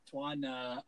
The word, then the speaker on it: Tuan: Twan underscore TMN. It's spelled with TUAN. Please do not put a W.